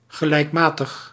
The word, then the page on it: régulier: France; [ʁe.ɡy.lje]